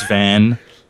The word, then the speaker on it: Van: Don't be jealous, Vaughn. – I am. – It's Van. – That should be a topic. If a name is spelled V-A-N, is it Van or Vaughn? – I'm pretty sure it's Van.